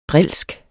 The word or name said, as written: drilsk